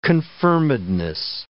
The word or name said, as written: confirmedness